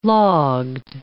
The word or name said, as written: logged